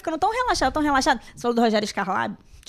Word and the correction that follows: Skylab: Skylab. É, falei certo? É, é, é, Skylab.